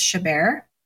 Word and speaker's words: Chabert: And it stars Lindsay Lohan, Rachel McAdams, Lacey, I looked up how to pronounce her name. I believe it's Chabert, and Amanda Seyfried.